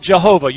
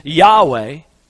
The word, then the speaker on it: Yahweh: Some people will pronounce it Jehovah. You've probably heard that. Better pronunciation of that in the Hebrew is Yahweh.